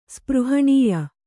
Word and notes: ಸ್ಪೃಹಣೀಯ: spřhaṇīya

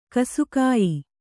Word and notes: ಗಾಸಿಗೆಯ್: gāsigey

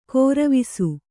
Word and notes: ಗರುವಿಸು: garuvisu

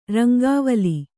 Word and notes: ರಂಗವಾಲೆ: Kannada

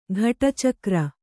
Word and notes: ಘಟಿಚಕ್ರ: ghaṭicakra; kannada

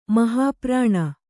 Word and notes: ಮಹಾಪ್ರಾಣ: mahāprāṇa

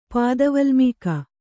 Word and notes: ಪಾದವಲ್ಮೀಕ: pāda valmīka

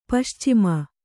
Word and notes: ಪಶ್ಚಿಮ: Kannada; paścima

